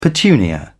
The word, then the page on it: petunia: British English